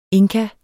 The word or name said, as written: inka